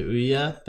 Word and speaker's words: Ouya: Is that how you pronounced it? – Right. Yeah, the Ouya.